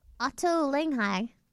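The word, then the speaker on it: Ottolenghi: That was Otto Langhi. That's how you pronounce it.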